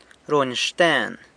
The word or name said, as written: runy xten